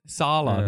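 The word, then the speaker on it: Saleh: Sala.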